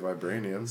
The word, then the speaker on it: vibranium: Who knows what you could do with vibranium? – So I thought. So from my limited knowledge of superheroes, I thought that the only vibranium, am I saying that right? – Vibranium.